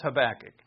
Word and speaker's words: Habakkuk: Because for half the time I say Habakkuk, and the other half I say Habakkuk. Turns out it's Habakkuk.